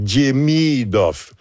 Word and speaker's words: Demidov: L'accent est sur la 2e syllabe. Donc, Demidov.